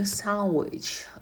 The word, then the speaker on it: sandwich: А в слове sandwich d звук не читается никогда. Sandwich, sandwiches.